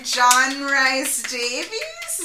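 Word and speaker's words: John Rhys-Davies: Oh, it's by John Rhys Davies? Is that how I'm pronouncing that? Yes, you'll know him as...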